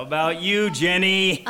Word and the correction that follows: Jenai: I'm ready, and it's pronounced Jenai, actually.